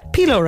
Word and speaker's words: pilau: Yeah, I had chicken tikka, pilau rice. Never know how to say that right. – Pilau rice, yeah.